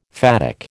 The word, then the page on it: phatic: American English